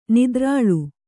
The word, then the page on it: ನಿದ್ರಾಲು: nidrālu